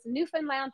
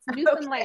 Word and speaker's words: Newfoundland: And I always would say it was Newfoundland like Disneyland. You know, that's how the tourism people, because I talk fast and some people would get mad that I wasn't saying it correctly, but I do know how to say it. It's Newfoundland.